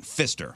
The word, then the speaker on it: Pfister: P-F-I-S-T-E-R. I'm assuming the P is silent. Pfister.